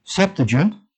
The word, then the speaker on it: Septuagint: And of course, the Septuagint, I believe that's how it's pronounced. I believe the accent is over the first E, Septuagint, was written in 300 to 200 BC by 70 to 72 Jewish scholars.